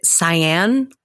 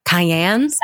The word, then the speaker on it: cyan: Is it cyan? Cayenne? – Cyan. – Okay. Cyan.